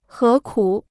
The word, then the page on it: 何苦: hé kǔ